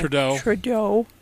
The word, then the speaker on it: Trudeau: Trudeau.